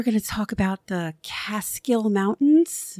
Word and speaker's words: Catskill: Down here, we say Catskill Mountains, and we spell it cat and skill.